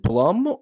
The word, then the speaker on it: Blum: But this one, how do you say B-L-U-M? Is it Blum or Bloom? – I say Blum because of Jason Blum. That's how his name is spelled, and that's how he pronounces it. So let's go with that.